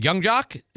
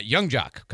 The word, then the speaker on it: Yung Joc: We've got Mardi Gras Mambo presented by the Ice Flyers featuring Lil Jon and, I'm going to say this wrong, Young Jock? Is that how you say this? Y-U-N-G-J-O-C. See, I want to say Jung. But that's a J, Carl Jung. Young Jock, coming up on January 11th.